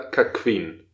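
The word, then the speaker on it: cuckquean: Ähm, und zwar ist es so, also es gibt einmal äh, Cockolding oder Kackqueen. Ja, das schreibt man zwar nicht mit EE, e, aber Q-U-E-A-N.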